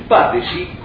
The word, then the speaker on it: Patesi: El Patesi, como suena, P-A-T-E-S-I, con acento en la...